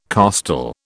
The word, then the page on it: costal: British English